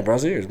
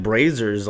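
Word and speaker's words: Brazzers: It's pronounced Braziers? – Yeah, Braziers, bro. What'd you think it was called? – I've been saying it like Brazers all this time.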